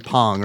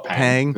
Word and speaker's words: Pang: I think it'd be Pong, right? Because it's manga, not manga, right? Super Pong? Pong? – Okay. – You looking up the pronunciation? – No, I'm just going to go with the westernized version and call it Pang. Because it reads Pang in my head.